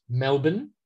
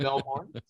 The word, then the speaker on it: Melbourne: Pronounced Melbourne as opposed to Melbourne.